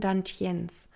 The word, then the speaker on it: Dantian: Vieles, auf was ich hier eingehe, kommt aus dem asiatischen Raum, so auch die nächste Komponente, und zwar die sogenannten Dantiens. Falls du das mal nachschlagen möchtest, Dantian geschrieben, also statt E mit A, aber mit E wird es ausgesprochen.